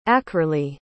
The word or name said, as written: ackerleigh